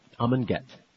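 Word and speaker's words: Amon Göth: Eamon Goat, is that how you say his name? – Get. – Eamon Get? – Amon Get.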